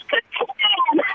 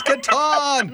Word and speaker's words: Catan: We looked it up while I was in the car right along with my kids, and I heard the pronunciation of like, all my life it's been Catan. Let me hear this. And yes, it's Catan. – It's Catan.